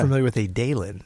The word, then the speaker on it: Dailen: Dalen. – Dalen is D-A-I-L-E-N.